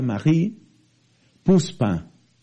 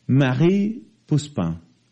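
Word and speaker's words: Marie Pousepin: Esta mujer se llama María, Marie Puspa. Se escribe Pousepin. Marie Puspa.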